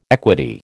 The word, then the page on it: equity: American English